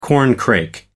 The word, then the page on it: corncrake: American English